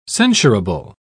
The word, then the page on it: censurable: British English